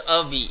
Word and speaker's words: Abhi: So let's say my name is Avi, A-B-H-I.